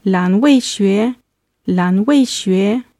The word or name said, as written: lanweixue